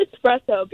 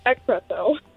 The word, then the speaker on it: espresso: It's pronounced espresso because it's actually this morning my mom was like, is it espresso or expresso? I'm like, it's expresso.